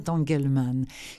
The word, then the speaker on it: Angelman: Il a 3 ans, il est atteint du syndrome d'Angelman, on va dire d'Angelman. Ça s'écrit A-N-G-E-L-M-A-N.